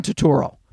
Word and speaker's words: Turturro: Another one is uh, uh, John, John Turturro. It has to rhyme with Oro.